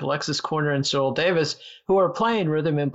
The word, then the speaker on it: Davies: Their last names were the Welsh spelling D-A-V-I-E-S that most Americans call Davies. but the pronunciation is, as you correctly mentioned, Davis.